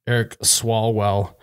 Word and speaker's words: Swalwell: Is that how you say his name? – Yep. – Eric Swalwell.